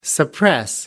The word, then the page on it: suppress: American English